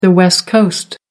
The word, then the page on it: west coast: American English